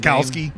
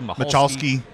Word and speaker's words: Michalski: When he got down toward the end of the interview, okay, his last name is spelled M-I-C-H-A-L-S-K-I. – Right. – Lots of us been mispronouncing it for years till he started playing. We found out that the C was silent.